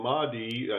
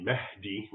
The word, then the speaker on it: Mahdi: So the Mahdi, as an English speaker would pronounce it, it's really Mahdi, which basically means guided.